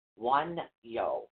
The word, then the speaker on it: Wunjo: This is W-U-N-J-O. Okay, it's actually pronounced W-O-O-N-Y-O. Wanyo. Wanyo. – Wanyo. – Wanyo. That's what it's pronounced.